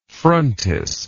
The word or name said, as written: frontis